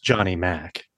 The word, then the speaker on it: John E. Mack: I believe it's pronounced Johnny Mack.